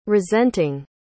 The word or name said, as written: resenting